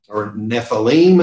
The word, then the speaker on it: Nephilim: And out of that, the offspring were these Nephilim, or Nephilim as it's pronounced, proper with this.